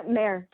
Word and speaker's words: Mère: I think she really also looked to her grandmother, who is called Mir. Is that how you pronounce it? Mm-hmm. Yeah, Mère, just like the French uh, for mother.